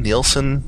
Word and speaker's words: Nielsen: Nelson? Nielsen? – Nielsen.